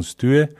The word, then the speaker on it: Stue: Eigentlich habe ich gehört, ich kann kein Dänisch, aber habe gehört, eigentlich wird es ausgesprochen Stühe. Aber aus, aus Gründen der internationalen Vermarktung haben wir uns dann entschlossen, es Stur auszusprechen und so hat sich es eigentlich auch ganz gut bis heute durchgesetzt.